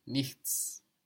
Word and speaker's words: nichts: To have C-H-T-S all together. Nichts. So you can think of nicht first and then add the S. Nichts. – Nichts. – In the same way, we thought about the yet of the word now and then we added the S-T, jetzt, to help us pronounce it. Either way, you will hear in colloquial German, nix, like N-I-X.